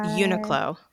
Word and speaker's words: Uniqlo: I don't know if I'm ever pronouncing it correctly, but is it Uniqlo? It's like U N. – I think it's Uniqlo. That's the way I always hear it.